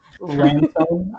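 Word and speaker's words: Ransone: Ransom, I think is how you pronounce it. – Yeah.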